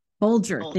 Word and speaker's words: Bolger: Bolger.